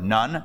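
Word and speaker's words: nun (נון): Nun. It's spelled like nun, but it's not nun. It's nun, the way it's pronounced in Hebrew.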